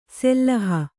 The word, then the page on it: ಸೆಲ್ಲಹ: sellaha